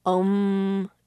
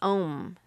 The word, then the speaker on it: Om: It's not unusual for a yoga class to begin and end the class with an Om chant. Again, this is based in Hinduism. When pronounced correctly, it actually consists of 4 syllables, A, U, M, and the last syllable is silent.